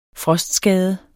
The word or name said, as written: frostskade